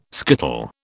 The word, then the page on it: skittle: British English